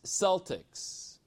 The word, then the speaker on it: Celtics: But they were known as Celtics, the hard C, and they were a nomadic tribe of warlike people.